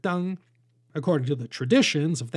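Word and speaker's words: Deng (灯): If they wrote the word for lanterns, also pronounced Deng, they'd be punished for breaking the taboo.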